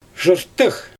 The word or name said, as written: ʒɯftɯx